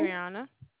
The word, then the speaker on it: Adrianna: What is Adriana. – Your name? – Adriana. – Oh, wow. How do you spell that? – A-D-R-I-A-N-N-A.